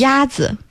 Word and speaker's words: 鸭子: 鸭子。